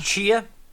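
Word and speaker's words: Tchia: Tashia? – Chia. – Chia? – Chia.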